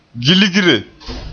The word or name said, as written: gilgre